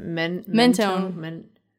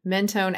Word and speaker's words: Mentone: Mentone. – Mentone, Alabama.